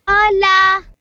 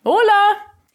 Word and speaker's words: Hola: Hola. – Go on, you give it a try. Hola. It starts with an H, but you don't pronounce that in Spanish.